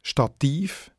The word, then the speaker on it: Stativ: Und wie man hört, hat Stativ noch die gleiche Betonung wie das lateinische Stativus.